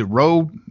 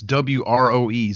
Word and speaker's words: Wroe: Is it Wroe? Is that how you pronounce it? It is Wroe. It's Wroe. – It's Wroe.